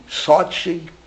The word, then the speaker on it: Sochi: Sochi.